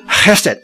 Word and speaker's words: hesed: 慈爱就是 hesed，诚实呢就是 emet， hesed emet，就是这个音非常像的，它是一双的东西。这个 emet 也就是我们今天所讲的阿门的来源。我们祷告说阿门，阿门是什么？是希伯来文，阿门就是我刚才所讲的是真实的啊，是真话啊，这个很重要啊。那么这个 hesed